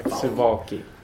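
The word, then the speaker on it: Suwałki: And here I find myself in beautiful Poland, in Suwalki. Suwalki, I'm so sorry. Suwalki. – Yes, it's difficult to pronounce. – I just have to remember the W's or V's.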